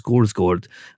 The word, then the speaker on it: Skarsgård: The gentleman who advised me a couple of weeks ago that Alexander Skarsgård is actually pronounced Skorsgård, and then you hear the island Faro.